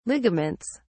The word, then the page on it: ligaments: British English